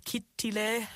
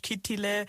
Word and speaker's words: Kittilä: Also wir sind zum Beispiel nach Kittilä, so spricht man das auf Finnisch, also auf Deutsch Kittilä, sind wir hingeflogen von Düsseldorf aus, weil es ab Köln keine Direktflüge gab.